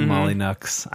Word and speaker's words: Molyneux: Oh, Molyneux. I believe that is the proper pronunciation.